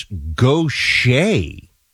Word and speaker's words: Gautier: How to pronounce Gautier, it's Gautier.